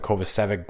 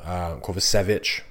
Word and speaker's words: Kovacevic: He had an assist on the Kovacevic goal. Kovacevic, rather. Sorry. I keep forgetting that I'm supposed to pronounce it Kovacevic, not Kovacevic.